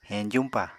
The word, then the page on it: heñjuñpa: Apatani